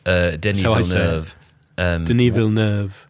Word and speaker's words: Denis Villeneuve: Denis Villeneuve was also the director of Blade Runner 2049.